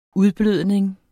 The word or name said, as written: udblødning